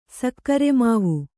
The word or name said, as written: ಸಕ್ಕರೆಮಾವು